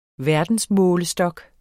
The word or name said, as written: verdensmålestok